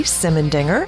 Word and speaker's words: Simendinger: Simmendinger, and that's spelled S-I-M-E-N-D-I-N-G-E-R. – And I'm at Neve Ellis, N-I-V-E-L-I-S.